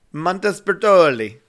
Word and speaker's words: Montespertoli: Montespertoli. – I said it right. – You didn't say it very Italian. – Montespertoli.